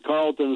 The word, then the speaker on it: Carleton: And Carlton is spelled with an E, CARLETON. People always leave the silent E.